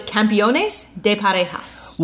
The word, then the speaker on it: Campeones de Parejas: I constantly say it wrong. – You just did. it's Campeones de Parejas. – Well, because I get Campeones and Campeanos confused because it's champion versus championship.